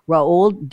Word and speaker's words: Roald: It's like Ronald without the N. Roald Dahl.